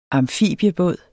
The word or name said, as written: amfibiebåd